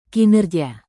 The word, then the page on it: kinerja: Indonesian